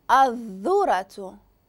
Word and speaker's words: الذرة: اذا هنا سمعتوا الالف ونقز من الالف للذا أذال. وكمثال شفنا ذرة ذرة الذرة الذرة يعني نقصت من الألف للذال ما نطقتهمش بجوج.